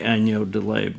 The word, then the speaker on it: agneau de lait: Or as the French say, agneau de lait, but I can't pronounce it very well. – You did very well.